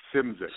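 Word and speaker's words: Szymczyk: Simzik.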